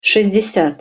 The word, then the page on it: sest-desjat: Russian